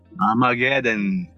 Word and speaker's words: Armageddon: Armageddon.